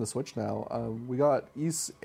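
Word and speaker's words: Ys: Yis franchise. I think that's how you pronounce it. Is it Yis? It's just spelled Y-S, but I've only ever heard it pronounced Yis. – I think it is Yis, but I'm not sure.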